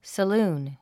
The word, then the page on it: saloon: səlúːn; サルーン